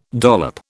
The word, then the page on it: dollop: British English